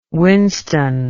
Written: winston

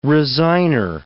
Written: resigner

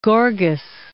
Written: Gorgas

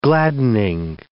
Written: gladdening

